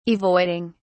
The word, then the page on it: avoiding: British English